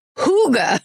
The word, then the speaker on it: hygge: But then, so thanks, Trisha, except then Sarah Tunderman wrote in and said, quick correction corner, hygge is pronounced hygge.